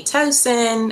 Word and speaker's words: Toson: You can find me on Instagram at Whitney Tosin.